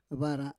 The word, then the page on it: vara: Roon